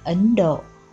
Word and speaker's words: Ấn Độ: Nay theo chánh âm nên đọc là Ấn Độ.